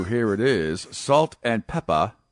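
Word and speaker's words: Pepa: And yes, it's not a Massachusetts accent. It's pronounced Peppa, P-E-P-P-A. – Thank you for that clarification. – It's actually just, this is. – It's just one P, P-E-P-A. – Peppa. – Yeah, you're right, one P.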